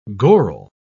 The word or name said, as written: goral